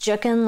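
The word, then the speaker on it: Jookin: It's J-O-O-K-I-N, but it's pronounced Jukin, like it's Juk, like book.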